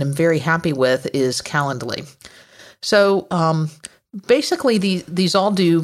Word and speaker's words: Calendly: The three that I want to talk about are Schedule Once, Calendly, and I'm not sure I'm pronouncing that's right, but it's C-A-L-E-N-D-L-Y. So it's like calendar, but with a D-L-Y at the end of it.